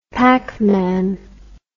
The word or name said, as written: pac man